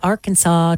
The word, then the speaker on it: Arkansas: And believe it or not, Arkansas took the top spot. A lot of people are still wondering why it's not pronounced like Kansas. Shouldn't it be Arkansas and not Arkansas?